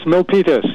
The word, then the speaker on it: Milpitas: Milpitas, yes.